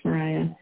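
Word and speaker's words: Mariya: You can find me on Facebook and Instagram, Love Coach Mariah.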